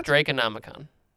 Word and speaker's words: Draconomicon: Draconomicon. Draco, the named dragon, Nomicon. – So is it dragon? – So that's a word that exists and is said Draco. – Okay. – What do you say, Draconomicon? – Yeah.